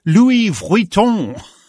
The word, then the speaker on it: Vuitton: Louis Vuitton.